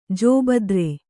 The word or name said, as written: ಜೋಬದ್ರೆ